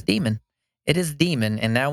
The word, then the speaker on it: daemon: So it says the word demon is an alternative spelling of D-A-E-M-O-N is an alternative spelling to demon. It's pronounced D-E-E-M-E-N, demon, in the context of computer software. The original pronunciation demon has drifted to daemon for some speakers.